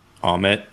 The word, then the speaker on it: Ammit: And I liked how, just the two gods and the differences, like how Ammit, am I saying that right? – Ammit. – Ammit, that's it. Yeah, kind of like omelet, but without the L.